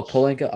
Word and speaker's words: Pelinka: I think that, yeah, like you said, Rob Pelinka? I don't want to say his name wrong. – The P. – Pelinka. The P. – Oh, Pelinka. Okay, I don't want to say his name wrong.